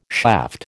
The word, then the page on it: shaft: American English